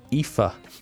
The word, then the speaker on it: Aoife: They found the message in the bottle dated July 17th, 2019 and signed Irish name A-O-I-F-E. I think it's Aoife. Yeah, close. Close enough. Aoife, I think, is the pronunciation there.